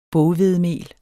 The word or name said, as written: boghvedemel